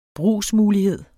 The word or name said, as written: brugsmulighed